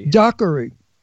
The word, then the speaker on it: Dockery: That's Michelle Dougherty. How do you say it? – Dockery.